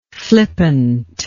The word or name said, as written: flippantly